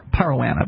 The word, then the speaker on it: Parowan: Or Parowan, I believe. The O is pronounced as an A, I think.